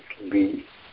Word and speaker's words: li: You have the verbal root li, l long I, li, which basically can mean to stick to, adhere to.